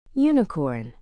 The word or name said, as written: unicorn